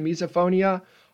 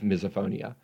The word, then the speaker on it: misophonia: I've never heard it pronounced with the long I though, misophonia. So it's misophonia, misophonia, but definitely not misophonia.